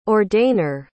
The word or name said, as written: ordainer